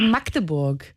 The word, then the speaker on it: Magdeburg: Okay, Magdeburg.